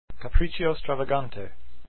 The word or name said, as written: stravagante